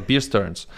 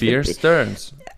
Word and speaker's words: Bear Stearns: Eigentlich ist es einfach Bierstearns. – Bierstearns. Aber warum sagt man nicht Bierstearns? Ja, weil das Stearns schreibt man ja genauso wie Englisch wirklich. – Bear Stearns.